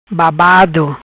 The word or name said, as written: Babado